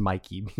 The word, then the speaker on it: Mikey: I'm 70% sure her name is pronounced Mikey. It's M-I-K-E-Y. I guess it could be a weird spelling of Mickey.